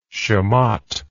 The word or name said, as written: chamotte